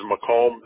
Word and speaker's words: Macomb: The city is Macomb. That's spelled with the M-A-C-O-M-B, like boy, Macomb.